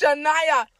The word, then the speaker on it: Senay: My Shania, name is pronounced Shania. You spell it S-E-N-A-Y.